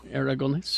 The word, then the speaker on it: Aragonés: How do you say his name? You're a cartoonist. – Aragonés. – I have a T-shirt. – Roll your R's.